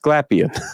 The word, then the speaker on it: Glapion: And I realized when I heard Mandy from Comic Sketch Art say his name that I've been saying it wrong all these years, but it's pronounced Glapion.